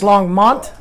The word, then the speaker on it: Longmont: It's Long Mount if anyone asks. – Yeah. – Am I saying it wrong? – Yeah. – What am I saying? – Longmont sounded like. – And it's Longmont? – Yes.